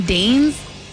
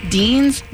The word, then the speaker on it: deigns: A woman deigns. Deigns. Deigns. – Spell it. – D-E-I-G-N-S. – That is. – I killed us all. – Damn. – Silent G words.